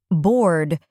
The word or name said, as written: board